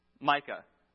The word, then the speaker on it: Micah: It rhymes with Hika. Micah.